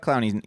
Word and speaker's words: Clowney: And Jadeveon Clowney, did I say his name right? – Yeah, you got Clowney.